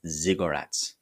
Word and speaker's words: ziggurats: Now, they're called ziggurats. It's kind of like cigarettes, but it's pronounced ziggurats, spelled Z or Z, depending on if you're using British English or American English. So Z-I-G-G-U-R-A-T-S, ziggurats.